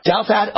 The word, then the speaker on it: Douthat: But I think it'll wind up being a 45 minutes to an hour debate between myself of Newsmax TV up against Ross Douthat, is that how you pronounce it? – Douthat of the New York Times.